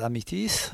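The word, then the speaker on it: Amethyst: Améthyste, c'est le titre de cet album qui doit se prononcer à l'anglais Améthyste.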